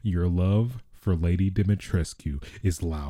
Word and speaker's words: Dimitrescu: her name, Lady Demetrescu. I'm totally butchering that. Lady Dimitrescu. That's what I'm going to say. Lady Dimitrescu.